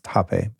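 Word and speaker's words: rapé: That's R-A-P-E-E. And they sell legit hape. It's kind of spelled like rape, but it's pronounced hape by most.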